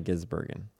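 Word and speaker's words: Shane Van Gisbergen: Ginsbergen. Shane Van Ginsbergen.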